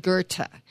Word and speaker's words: Goethe: Again, it's Johann Wolfgang von Goethe.